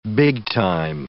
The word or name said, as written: big time